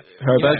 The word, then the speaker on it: Højbjerg: Um, I'm going to get the pronunciation wrong, but it's Huberg. – Oh, yeah, Huberg.